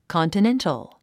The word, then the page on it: continental: kὰntənéntl; コンティネントォ